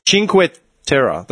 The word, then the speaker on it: Cinque Terre: Cinque Terre. It says how to pronounce the place. – Yeah, you said that correctly.